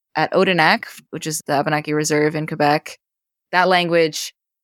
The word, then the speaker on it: Abenaki: You also sing, I'm going to maybe butcher my pronunciation of this in Abenaki. – Abenaki, yeah.